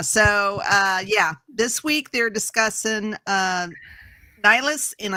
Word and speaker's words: Nihilus: They are discussing Nihilus.